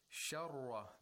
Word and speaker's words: شَرَّ: Sharra, the ra has tashdeed, and fatha has to be pronounced with tafkhim and without takreer. If you say sharra, sharra dhalika. – Yeah, the trilling sound should not be heard.